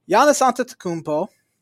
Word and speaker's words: Giannis Antetokounmpo: Giannis Antetokounmpo. – Good pronunciation.